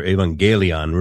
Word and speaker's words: evangelion: The word evangelium in Greek, or evangelion, really is the Greek pronunciation.